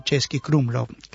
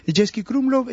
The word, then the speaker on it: Český Krumlov: Český se escribe C-E-S-K-Y, y Krumlov se escribe como se pronuncia, solo que con K.